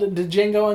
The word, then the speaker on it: Django: Is it really called Django Unchained? I thought the D was silent. – No, the D is silent. – The D is silent. You're right.